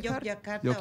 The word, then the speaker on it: Yogyakarta: Yogyakarta? – You can say Yogyakarta.